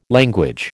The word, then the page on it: language: American English